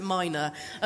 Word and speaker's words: minas: And I said, please, I don't know what's right, but please pronounce it minor.